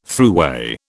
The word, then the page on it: thruway: British English